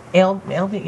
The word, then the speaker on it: Ailbe: What is it? – Albie? – Yes. A-I-L-B-E.